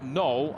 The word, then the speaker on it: Noel: Jacob Robinson forcing out null on the sideline. His name is spelled N-O-E-L as in Noel, but it's pronounced null according to Iowa State's communications folks.